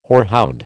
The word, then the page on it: hoarhound: American English